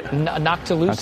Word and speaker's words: noctilucent: Isn't the, how do you say that, nocticulent cloud, right? – Noctilucent? – Nocticulent cloud? Noctilucent. Yeah, yeah. I've mispronounced it like 1,000 times.